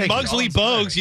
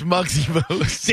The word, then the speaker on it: Muggsy Bogues: Yeah, I mean, when Muggsy Bogues, you know, dunked, that was more impressive. – It's Muggsy Bogues. – Damn it, I knew I was going to say that wrong.